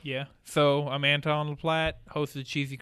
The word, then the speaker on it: Anton: Did I say that right? – Yeah, Anton okay. LaPlatte. – I keep wanting to put a W.